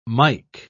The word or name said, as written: mike